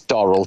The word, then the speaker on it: Dorrell: My surname is Durrell, D-O-R-R-E-L-L.